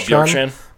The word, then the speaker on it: Bjorkstrand: Does does how do you say his name? York York. – Oh, Bjorkstrand.